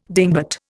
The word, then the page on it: dingbat: Indian